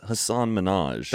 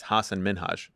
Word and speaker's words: Hasan Minhaj: I thought it's Hasan Minhaj. – That's what it used to be, but he's reclaimed the proper pronunciation. It's Hasan Minhaj.